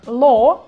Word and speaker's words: law: Звучит это слово как law в британском варианте и law в американском варианте. Ну, то есть W или звук W в конце слова вообще не произносится, а вот звук O растягиваем, как бы широко раскрывая рот.